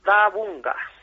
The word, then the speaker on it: Bárðarbunga: Bárðarbunga.